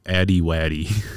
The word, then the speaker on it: Addy Waddy: No, I'm at that, but my name is Addy Waddy.